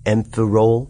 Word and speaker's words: Amphirole: So there's this invention I want to tell you about called the Amphirole. Like amphibian. Amphirole.